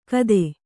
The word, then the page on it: ಕಥೆ: kathe